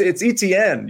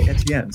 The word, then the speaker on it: Etienne: You were like, it's Etienne. You're pronouncing it wrong. Well, it's French. And I promise you, in France, they don't say Etienne. They say Etienne.